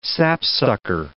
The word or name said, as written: sapsucker